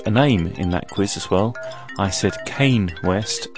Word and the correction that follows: Kanye: and it's Kanye West.